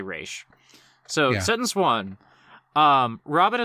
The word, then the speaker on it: Ra's al Ghul: Robin is abducted, and I'm gonna say Ra's al Ghul.